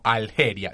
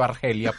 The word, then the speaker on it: Argelia: Ahí hay, digamos, como un no hay un acuerdo, porque, porque mucha gente lo pronuncia en español como Algeria. Yo siempre he dicho Argelia, pues.